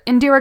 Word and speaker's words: Indira: But I believe it's Indra. – Indira Gandhi.